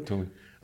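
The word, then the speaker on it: Thule: Is it pronounced Tully? – Tully.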